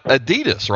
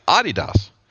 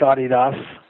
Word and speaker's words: Adidas: But the other, and some of our listeners may not know this, but it's not the correct pronunciation is not Adidas, right? It's Adidas. – That's correct, yeah. If you talk to anyone in Europe, specifically in Germany, they'll tell you it's Adidas.